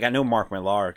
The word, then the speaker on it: Millar: I'm even saying his name wrong. I'm sorry. – Millar. – Yeah. That's how you say it? – Millar, yeah.